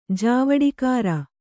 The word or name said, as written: ಜಾವಡಿಕಾರ